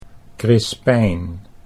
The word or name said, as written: Crispijn